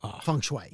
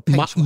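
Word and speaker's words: Peng Shuai: Anyway, the woman's name is not pronounced feng shui. – Well, or peng shui.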